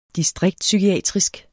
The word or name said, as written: distriktspsykiatrisk